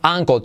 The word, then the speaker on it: ankle: Eh, y la L al final es casi imperceptible, no es ankle, sino ankle, no es ankle, sino ankle. ¿Vale? Es algo muy típico en otros los hispanos el decir la L como española. Y no, casi no se pronuncia al final de palabra, ¿vale?